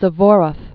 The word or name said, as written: Aleksandr Vasilevich Suvorov